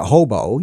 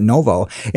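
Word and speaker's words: Novo (Ново): in the Cyrillic alphabet the N is expressed by H and uh, V is usually B. So it's really Novo. You're saying, you know, you're not pronouncing it hobo, you're pronouncing it Novo.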